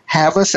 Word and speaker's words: Havas: I want to quote from your book, David Jones Global, CEO of Havas. I hope I'm pronouncing it correct. – Havas. Yeah.